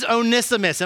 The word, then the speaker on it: Onesimus: And the name is Onesimus.